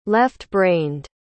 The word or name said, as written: left-brained